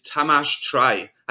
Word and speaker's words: Tamas Traj: And today, the enemy at the gates is Tamas Traj.